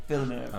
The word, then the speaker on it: Villeneuve: Villeneuve. – Why are you so easily able to say it? – Villeneuve. – And I can't. It's just because it's French, isn't it? Denis Villeneuve.